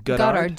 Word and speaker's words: Goddard: engineer Robert Goddard, I don't know how to say his last name. – Goddard. G-O-D-D-A-R-D.